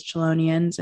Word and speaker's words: Chelonian: Is that how you say it? Chelonian? – Mm-hmm. Yeah.